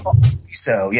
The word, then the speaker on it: Seeso: CISO, yeah.